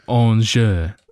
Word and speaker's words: Angers: Ligue 1 or Angers.